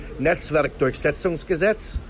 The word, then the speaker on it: Netzwerkdurchsetzungsgesetz: The actual pronunciation of that law is Netzwerkdurchsetzungsgesetz or Netzwerkdurchsetzungsgesetz.